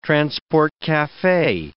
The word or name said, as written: transport cafe